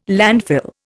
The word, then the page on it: landfill: Indian